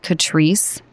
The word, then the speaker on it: Catrice: First of all, it's, I believe you pronounce it Catrice, C-A-T-R-I-C-E.